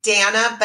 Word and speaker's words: Dana: You can either look up Hot Mess to Not Mess, or you can find it under my name, Dana Banana, not really Banana, but that's how you say it, Reed Nuesca.